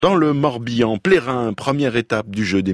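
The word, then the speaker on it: Ploëren: La commune de Plérin. Oui, on dit bien Plérin. Alors, le nom de la commune s'écrit P-L-O-E-R-E-N, mais se prononce Plérin.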